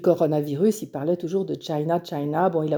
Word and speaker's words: China: En anglais, ça se prononce China, comme la Chine.